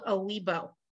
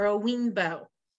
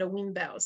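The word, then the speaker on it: Oyinbo: So anybody who's not black is called Owibo or Owinbo. That's how they pronounce it, Owinbo.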